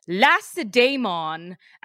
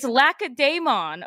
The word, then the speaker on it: Lacedaemon: he kept calling Sparta the beautiful land of Lacedaemon. And I'm like, oh my God, this is, I mean, I know it's romanticized because there's a C, but it's Lacedaemon, okay? Like anyone who knows Greek.